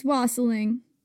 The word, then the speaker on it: wassailing: It's wassailing. Rhymes with fossiling.